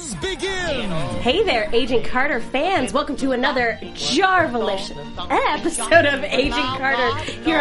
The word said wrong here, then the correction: Jarvelous: I couldn't even say it. – It's Jarvelous. – Jarvelous. – It's a long A at the beginning.